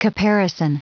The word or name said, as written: caparison